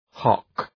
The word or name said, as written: hock